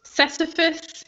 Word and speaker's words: Sisyphus: Sisyphus, yeah. Again, I'm not really bad at pronouncing it. – It's like syphilis, but it's not.